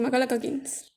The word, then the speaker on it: Macaulay Culkin: Protagonizada por Macaulay Culkin, no sé si lo dije bien. Sí, Macaulay Culkin.